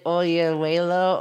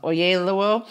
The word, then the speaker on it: Oyelowo: So she, it's like, so the deal is like the guy who's David Oyelowo. Oh God, I'm gonna say that wrong. Oyelowo.